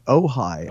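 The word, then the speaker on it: Ojai: Ohi, and am I saying that right? – It's pronounced Ohi.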